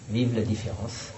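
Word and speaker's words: vive la différence: And how do you say the vive la? – Vive la difference.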